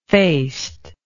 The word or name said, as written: faced